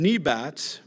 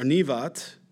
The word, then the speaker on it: Nebat: The son of Nebat, or Nevat, because B's are pronounced like V's in Hebrew, Nevat, the Ephraimite, and Zerda, whose mother's name was Zerah, a widow, also rebelled against the king.